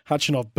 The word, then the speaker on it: Khachanov: Come on, Joker, a silent K if you don't mind, in that little grab. And, of course, the only time Khachanov beat Djokovic was at the Paris Masters, a Masters 1000 going back 3 years ago.